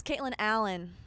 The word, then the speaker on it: Caitlin Alyn: See, it's Caitlin Allen. – Caitlin Allen. – So everybody mispronounced it, so it's good that we're here.